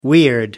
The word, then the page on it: weird: American English